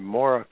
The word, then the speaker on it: Moragh: It's In Harmony, and it's written by an author named Maura Carter, M-O-R-A-G-H. It's sort of a Celtic way of pronouncing the name, Maura Carter, and it's called In Harmony, Biography of Jack Blanchard and Misty Morgan.